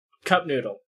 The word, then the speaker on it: Cup Noodle: So last time I came here, I brought you guys Cup of Noodle. – Cup Noodle. – Cup Noodle. – Say it right.